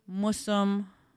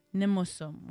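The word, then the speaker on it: nimosôm: I know that we say musum, or down south they say mushum, but the correct pronunciation is actually nimusum when you're saying my grandpa, my grandfather. Gimusum, that's your grandmother. So that ni and that ge at the beginning is very important.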